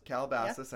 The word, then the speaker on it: Calabasas: Then we're going up to Calabasas. Did I say it right? – Calabasas.